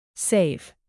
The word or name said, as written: save